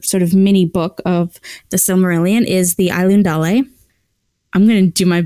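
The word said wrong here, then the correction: Ainulindalë: In fact, I said that wrong now that I'm looking at it. The Ainulindale. I left out a syllable. Ainulindale.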